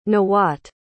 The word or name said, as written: nawat